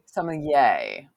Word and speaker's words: sommelier: We're totally jumping over a whole bunch, but you're a sommelier. – I am. – Did I say that right? – I'm a sommelier. – Sommelier.